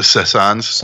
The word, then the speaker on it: Saisons: The Saisons.